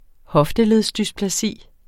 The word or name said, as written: hoftedysplasi